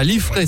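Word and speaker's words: Liffré: Ça va très bien, tu es à côté de Rennes, à Liffray, c'est ça? Je prononce exactement.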